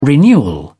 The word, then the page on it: renewal: British English